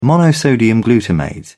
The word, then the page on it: monosodium glutamate: British English